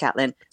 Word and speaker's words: Caitlin: Everybody else who spells their name that way, they would pronounce it Caitlin. I picked it out of a Jilly Cooper book when I was 13, and I just thought it was pronounced Catelyn.